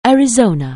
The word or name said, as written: Arizona